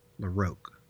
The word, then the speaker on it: LaRocque: Or how do you pronounce that? – It is from a video I found on YouTube from the man himself, Greg LaRocque. – Oh, like, okay, LaRocque? – LaRocque, yeah.